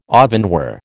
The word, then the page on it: ovenware: American English